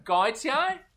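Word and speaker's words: Gotye: They did the cover of that South African guy, Gwethi. – Is that what? Seth Fisher's nephew? – Gautier? – It's pronounced like Jean-Paul Gaultier.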